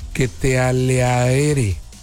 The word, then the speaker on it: Ketelaere: ¿Cómo se pronuncia esto? Ketelaere. Usted sabe, ¿no? Lo escribo con K. Ketelaer.